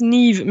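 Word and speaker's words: Niamh: son prénom, ça se prononce Nive, mais ça s'écrit Niam, j'ai envie de te dire que il y, a un truc qui va pas dans le pays.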